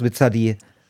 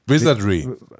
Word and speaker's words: Wizardry: wie spricht man das aus? Wizardy? – Wizardry.